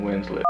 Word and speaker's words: Winslett: Marvin Winslet. – How do you spell that, the middle name? – W-I-N-S-L-E-T-T.